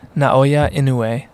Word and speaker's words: Naoya Inoue: Naoya Inoue. So Inoue.